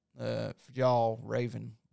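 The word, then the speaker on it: Fjällräven: And I think the reason it costs so much is because it has this logo, Fjall Raven logo. But I just wanted to quickly check out. – And it's pronounced, by the way, I looked it up online. It's Fjallraven.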